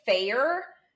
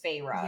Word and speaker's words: Feyre: But also, I pronounced her name Fair until... I think in the second book or the end of the first one, like, it had the pronunciation in parentheses for something, and then it was Feyre.